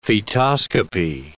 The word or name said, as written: fetoscopy